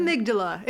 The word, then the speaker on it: amygdala: So they isolated that part of the brain, the amygdala. Did I pronounce that correctly? – Amygdala. It was close. What? You're good. You're good. – All right. Amygdala. My pronunciation is quite off.